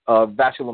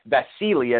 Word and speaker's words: Vasily: I mean, this is a guy I think we wanted him to see in the ring with Vasily Lomachenko, or I should say Vasily, as it's pronounced, Lomachenko.